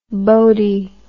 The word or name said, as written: boatie